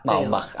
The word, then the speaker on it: Baumbach: Baumbach. – Baumbach, ahí está, buenísimo.